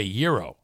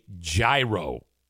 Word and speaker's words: gyro: I'm pretty sure that food is called a gyro. Like, that's how it's pronounced. Chris says Kenny is implying, Chris has a Kenny to English translation book, and he says Kenny is implying a hard G.